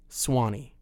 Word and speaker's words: Swanee: The Suwannee River itself, which runs through the middle of Florida, is spelled S-U-W-A-N-E-E. The song removes that U to make it a two-syllable word, not Suwannee, Swanee.